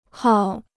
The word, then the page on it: 号: háo